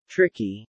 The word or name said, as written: tricky